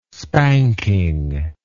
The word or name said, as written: spanking